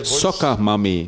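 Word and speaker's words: Soccer Mommy: Und ihrem aktuellen Album, also nicht wie Saugen, Soccer, sondern wie Fußball. – Also Soccer. – Ah, ja. – Soccer Mami, so spricht man das aus.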